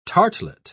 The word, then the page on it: tartlet: British English